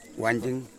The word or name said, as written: wanjing